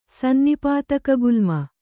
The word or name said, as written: ಸನ್ನಿಪಾತಕಗುಲ್ಮ